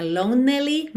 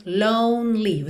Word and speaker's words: lonely: Atenção, mais um E que não é pronunciado, tá bem? Então não é lonely, mas lonely. Você pensa de ir do N direto pro L.